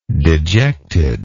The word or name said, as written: dejectedness